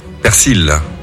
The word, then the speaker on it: persil: Alors normalement c'est persil, ok, ouais. D'après le dictionnaire de l'Académie française. Mais le nord et le sud du pays prononcent quand même le L, donc t'as le droit quand même aux deux.